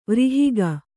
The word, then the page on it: ವ್ರಿಹಿಗ: Kannada